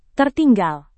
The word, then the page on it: tertinggal: Indonesian